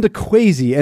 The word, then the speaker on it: Quazy: So the band Kwesi, and it is spelled Q-U-A-Z-Y. Do I have that correctly? – That's right. – Tell us about Kwesi and how it is you ended up in the Nebraska Rock and Roll Hall of Fame.